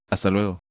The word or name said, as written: ¡Hasta luego!